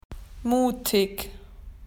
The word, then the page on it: well done: Australian English